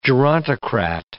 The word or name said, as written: gerontocrat